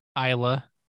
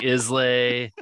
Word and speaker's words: Islay: How do I say it? – Isla. – Whatever. I feel like we went through this like three times. I was saying Islay, Islay. – Yeah. – What is it again? – Isla.